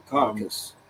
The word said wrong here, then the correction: caduceus: It's the Caduceus, and it's the Caduceus. Say that wrong.